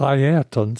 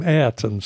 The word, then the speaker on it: Ayrton: And I had become sloppy, like a lot of people, and was now calling him Ayrton Senna. So I resolved in 1994 that I would use his correct pronunciation of his Christian name.